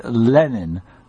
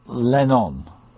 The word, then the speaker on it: Lenin: It's a bit overplayed to say Lenin, because that's not quite how you say it. But it's definitely Lenin, not Lennon.